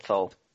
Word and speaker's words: Thole: Don't know how to say the name. Thiel? – Thole. I pronounce it Thole.